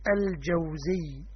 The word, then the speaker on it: الجوزي: لو قرأ أول الكتاب لعلم أن النطق الصحيح الجوزي بفتح الجيم وسكون الواو.